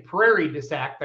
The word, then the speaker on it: Prairie du Sac: Greg, the benefit pole, if people aren't familiar with it, the American Family Children's Hospital pole used to be in Prairie du Sac. Did I say that right? – Yep.